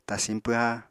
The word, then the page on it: tasiñ pwha: apatani